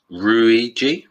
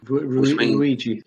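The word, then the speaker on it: ruiji: Well, Luigi is actually translated from a Japanese word, which I'm now going to butcher the Japanese language, which is Ruigi. – Ruigi. – Yeah. R-U-I-J-I. I'm assuming it is Ruigi, which means similar.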